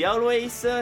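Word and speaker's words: Alvvays: Ci ascoltiamo gli Always, si scrivono con due V ma si pronuncia W, ci ascoltiamo Next of Kin.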